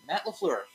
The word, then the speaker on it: LaFleur: Now, the Packers went and got Matt Floor. Floor, how you say his last name? Matt Floor, I think is how you say it. For the Packers, they hired their Matt LaFleur. – Yeah, LaFleur. There you go.